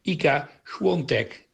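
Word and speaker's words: Iga Świątek: Ika Swiatek, zeggen ze op zijn Pools.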